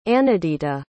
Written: anidita

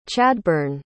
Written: Chadbyrne

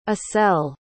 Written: acel

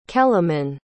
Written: keleman

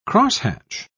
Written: crosshatch

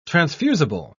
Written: transfusible

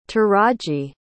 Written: taraji

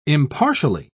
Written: impartially

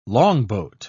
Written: longboat

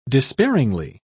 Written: despairingly

